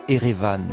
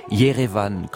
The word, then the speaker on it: Yerevan: L'Arménie et ses 800 000 habitants, abandonnés par les Alliés, se replient alors sur ses montagnes et sur sa nouvelle capitale, Erevan, ou plutôt Yerevan, comme on la prononce là-bas.